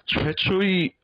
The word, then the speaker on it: 최초의: 와, 나는 우리 회사 최초의 외국인이야. – 최초의, yeah, that's how it's spelled, but you do pronounce it 9 times out of 10, just 에, 최초의 외국인이야. 나는 우리 회사 최초의 외국인이야.